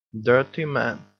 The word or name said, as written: dirty man